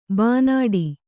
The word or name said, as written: ಬೈನಾಡು